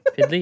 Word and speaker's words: PDLY: P-D-L-Y. Piddly? – Piddly, yeah.